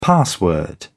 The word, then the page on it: password: British English